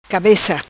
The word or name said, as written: Cabeca